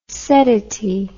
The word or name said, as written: seddity